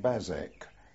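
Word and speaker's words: Basècles: at a place called Bazec. It's spelt B-A-S-E-C-L-E-S.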